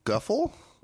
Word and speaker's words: Guffle: No, Guffle. That rhymes with Nuffle.